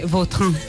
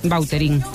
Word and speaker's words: Vautrin: Pues, es que si me pregunta exactamente cómo se pronuncia, pues así, claro, pero sí. Yo ya soy Bauterín. – Ya. – Está españolizado total.